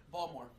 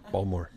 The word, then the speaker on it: Baltimore: Baltimore. Pronounce that word. – Balmore. – Balmore. – B-A-L-M-E-R. – Yep. – Okay. Gary, pronounce that word. – Picture. – P-I-X.